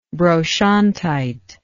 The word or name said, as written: brochantite